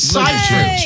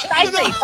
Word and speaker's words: Seyfried: Amanda Seyfried from Mean Girls. – Mm-hmm. It's Seyfried. She pronounced her name Amanda Seyfried, but I didn't. – Oh, it's Seyfried.